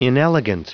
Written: inelegant

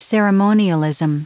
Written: ceremonialism

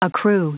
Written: accrue

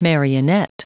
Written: marionette

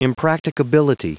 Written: impracticability

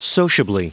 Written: sociably

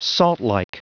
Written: saltlike

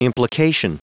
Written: implication

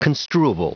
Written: construable

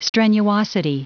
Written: strenuosity

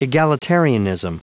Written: egalitarianism

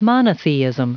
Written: monotheism

